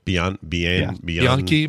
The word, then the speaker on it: Bianchi: By the way, we're talking about Daniela Bianchi's legs. How do you say her name? – Bianchi, maybe? – Bianchi.